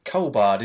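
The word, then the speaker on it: Culbard: Colbard. Is that how you say it? – Yeah, I think so.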